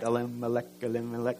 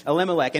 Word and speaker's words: Elimelech: And it kind of reminds me of the jungle, the lion sleeps tonight, right? Elimelech. But that's an easy way to remember how to pronounce that. Elimelech.